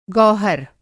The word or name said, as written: गौहर